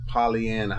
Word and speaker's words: Pollyanna: Never heard of Pollyanna. How you spell it? – P-O-L-L-Y-A-N-N-A, Anna. Yeah, Pollyanna.